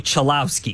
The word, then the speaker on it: Chulowski: It's not like it's spelled L-O-U. And you would be like, oh, it's spelled Lau. No. – Select Rock Chulusky. – It's spelled L-O-W like cow. It's spelled L-O-W, Lau, like cow. – Chulowski. – Like, anybody would look at this name and say Chulowski.